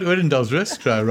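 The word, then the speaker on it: Odendaalsrus: We've got a question from Willy Potgieter from, I'm going to try to pronounce this correctly, Oordendaalrus in the Free State. Did I get it right? – You got Oordendaalrus right, the second part.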